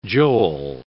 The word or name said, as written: Joel